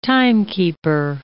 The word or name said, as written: timekeeper